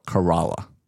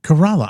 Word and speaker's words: Corolla: You mean Corolla? – So it's spelled like the car, Corolla, but the locals call it Kerala. – Kerala.